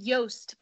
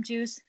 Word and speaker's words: Joost: It's called Joost, but everybody, English speaking, they call him Juice, so we call him Juicy.